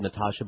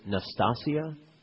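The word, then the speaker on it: Nastassja: Well, I've always said Natasha, but apparently it's Nastasia. – Oh, you're right. I didn't look at it that closely. – All my life. – Yeah, it's got an S. Nastasia.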